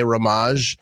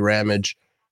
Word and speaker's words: Ramage: Yeah, and like I remember like my kids' teachers would always say my name wrong. They would say Ramaj. And they're like, oh, I'm real sorry, could be told. I'm like, it's actually Ramage.